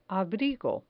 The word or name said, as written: abrigo